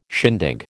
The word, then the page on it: shindig: American English